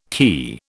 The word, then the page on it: cay: British English